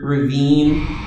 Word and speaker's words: ravine: Ravine there in line 4, by the way, it's spelled the same as the word ravine, like a valley or a canyon, but it's ravine, like related to ravenous.